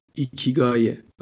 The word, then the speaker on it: ایکیگای: ایکیگایه آره خود تلفظ سخته ولی تلفظ درستش به نظر همینه